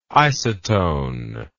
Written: isotone

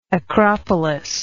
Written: acropolitan

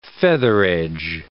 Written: featheredge

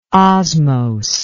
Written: osmose

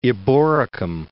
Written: Eboracum

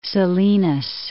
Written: Salinas

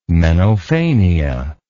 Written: menophania

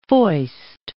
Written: foist

